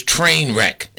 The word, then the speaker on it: TNRCC: TNRCC, you know, the way they pronounce that acronym was train wreck.